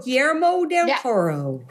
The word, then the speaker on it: Guillermo del Toro: Oh, Guillermo del Toro.